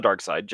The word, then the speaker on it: Darkseid: It's Darkseid, sir. All right. I pronounce it both ways. It's okay. – But it's wrong, though. – No hate. But I prefer the dark side just because it sounds better.